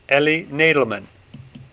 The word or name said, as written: Nadelman